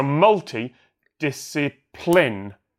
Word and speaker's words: multidisciplinary: It's on the first, right. So multi-disciplinary. Discipline. So you got to land on the first and multi-disciplinary. You got to land on the first and fifth syllable of a seven-syllable word, and I think that's just really confusing because you've got that long string of unstressed syllables in the middle.